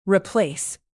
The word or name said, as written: replace